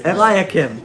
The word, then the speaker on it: Eliakim: Eliakim. – Thank you. Some words I just don't click in my brain. Eliakim, I will clothe him with your robe and fasten your sash around him and hand your authority over to him.